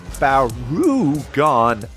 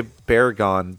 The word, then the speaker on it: Barugon: And we are back to talk about Gamera versus Barugon. I keep wanting to say Baragon, but it's Barugon. So I stuttered before I even got to Gamera because I was like, don't say it wrong.